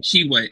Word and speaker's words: Tshiebwe: Tshiebwe. It's just Tshiebwe. The T is silent.